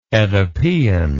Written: oedipean

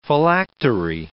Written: phylactery

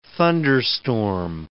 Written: thunderstorm

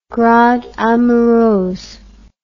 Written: grande amoureuse

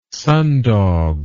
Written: sundog

